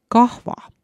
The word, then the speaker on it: kahva: Ja moslem väidab, et tema tassis on hoopis HV-ga kirjutatud kahva.